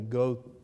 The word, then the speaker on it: Goethe: I've always pronounced it as Goethe, but I believe it is actually Goethe, I guess like the beard that you might try to grow as a young teenage man.